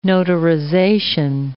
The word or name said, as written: notarization